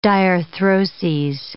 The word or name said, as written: diarthroses